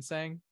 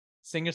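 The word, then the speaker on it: Singh: Is it Sing or Sang? Sing or Sang. I could be wrong. – I'm sure you could pronounce it depending on how that person pronounces it. – It's probably right, Sing.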